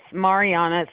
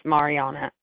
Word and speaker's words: Mariannie: It's Mariana, so it's spelled Mariannie, but it's Mariana.